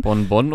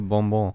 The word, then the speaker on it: Bonbon: Bon Bon oder Bonbon? – Bonbon, ja, sorry.